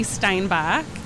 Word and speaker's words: Steinbach: Yeah, my name is Katy Steinbach,